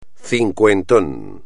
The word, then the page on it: cincuentón: espagnol